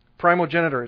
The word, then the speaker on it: primogeniture: Primogeniture, it's primogeniture.